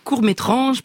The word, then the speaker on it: courts-métranges: Oui, ce n'est pas une erreur de prononciation. Il s'appelle courts-métranges parce qu'ils mettent à l'honneur des courts-métrages fantastiques et insolites, des films étranges.